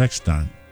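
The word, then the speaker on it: Eckstine: Yeah, it was like he pronounced it Eckstein.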